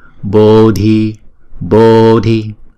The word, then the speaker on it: Bodhi: Parasangate Bodhi Bodhi, chữ O là diphthong chúng ta đọc hai nhịp. Chữ D và chữ H ghép lại chúng ta đọc là d d, Bodhi Bodhi.